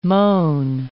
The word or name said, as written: moan